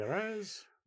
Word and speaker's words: Figueres: You know, all the Paris stuff, Cristina Figueres, I can never pronounce her last name. – Figueres.